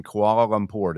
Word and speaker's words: Kuala Lumpur: So you guys are in Kuala Lumpur.